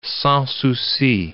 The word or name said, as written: sans souci